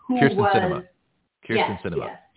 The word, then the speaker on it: Kyrsten Sinema: The Kyrsten Sayma, the Arizona Democrat that is also a professor and has been for the last, 18 years at ASU, Kyrsten Sinema. – Sorry that I said her name wrong. – Movies, cinema, theater, Kyrsten Sinema.